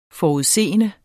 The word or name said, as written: FORUDSES